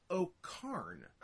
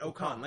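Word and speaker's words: O'Khan: Because now that he's in New Japan, it's the Great O'Con, like Genghis Khan.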